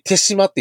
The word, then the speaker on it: 豊島: これ、て、そう、あの、豊島、豊島と書いて手島って読むんですよ、これ。